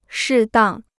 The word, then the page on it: 适当: shì dàng